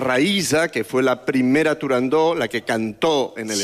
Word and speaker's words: Turandot: No se debe pronunciar Turandot. – Ah, no, Turandó. – La ópera se titula Turandó.